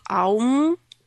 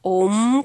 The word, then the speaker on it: Аум: И из северной головы выходит звук «А», из западной головы звук «У», из южной головы звук «М», и из восточной это точечка, и получается, что звук «аум» или знаменитый звук «ом», как мы его иногда произносим, тело Шивы формирует этот звук.